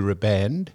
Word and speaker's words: riband: No such thing as blue ribbon event. It's blue. It's blue riband. – No, it's not. It's please. It's blue riband. Blue riband event. – How do you spell it? – Riband. R I B A N D. – It's a ribbon. It's a ribbon. – Okay. It's not ribbon, Don.